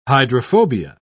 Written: hydrophobia

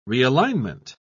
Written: realignment

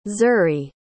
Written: zuri

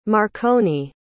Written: marconi

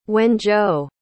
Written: wenzhou

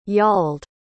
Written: yauld

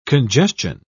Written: congestion

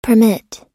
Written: permit

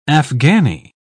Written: afghani

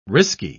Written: risky

